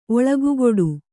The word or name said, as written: ಒಳಗುಗೊಡು